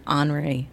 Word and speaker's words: Henri: I'm like, I've been pronouncing it Henry this whole time. And all of a sudden, here, Henri. I'm like, wow. – Well, yeah, okay. It's the French spelling.